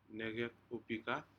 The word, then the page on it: necec u prikad: Aneityum